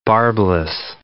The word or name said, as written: barbless